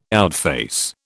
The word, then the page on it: outface: British English